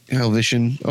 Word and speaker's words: Helvetian: Every Roman was speaking German, and every Helvetican, is that how we should pronounce that, Helvetican from Helvetica? – Helvetian.